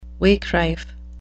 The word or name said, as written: wakerife